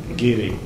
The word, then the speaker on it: Garioch: Is that the right pronunciation, Geary? Geary? Yeah. It's spelt Garioch, but anyway, Geary.